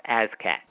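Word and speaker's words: ASCAC: is pronounced ASCAT, clearly advocated this perspective in their writings.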